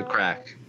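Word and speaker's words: craic: But for now, Pete, what's the crack? – Did I say that right? Not much, man. Yeah, perfect.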